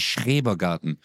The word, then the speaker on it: Schrebergarten: Ich sag jedes Mal Strebergarten, aber es heißt Schrebergarten. Schrebergarten.